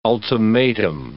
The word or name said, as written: ultimatum